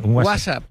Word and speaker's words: WhatsApp: No, peor aún, con acento en la primera. WhatsApp, ¿no? Un WhatsApp.